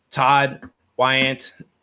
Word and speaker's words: Todd Weyandt: Well, guys, we have an awesome guest today, Todd Weyandt.